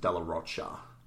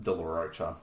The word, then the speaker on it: De La Rocha: They pronounce it De La Rocha.